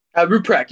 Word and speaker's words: Rupprecht: But Jonathan, and this name is a tough name to do, Ruprecht. Did I do it okay? – Ruprecht, you're pretty close.